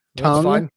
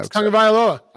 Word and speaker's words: Tagovailoa: I will teach the whole world how to say this last name correctly because it's not that hard. Don't ask me to say his first name though. – Tagovailoa.